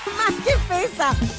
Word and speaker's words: Magefesa: Hasta 30% de descuento en las marcas Oster, Doral, Mega, Mega Fesa, ¿lo dije bien? – Mega Fesa. – Ah, de Magefesa.